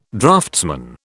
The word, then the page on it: draftsman: British English